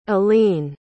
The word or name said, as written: aline